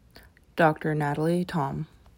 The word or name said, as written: Dr. Natalie Thom